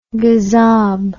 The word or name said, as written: gazob